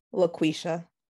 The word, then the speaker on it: LaQueshia: LaQueshia.